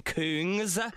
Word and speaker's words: Kungs: I hate saying the band name, the DJ name Kungs on the radio. You know, K-U-N-G-S. – Yeah, but it's Kungs. – No, it's pronounced Kungs officially via the record label. That's what they said. So now on air, it's like, that's Kungs.